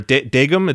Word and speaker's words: dagum: That is, it's called a tegum, or a dagum. It's spelled with a D, but the sound is sort of in between a D and a T.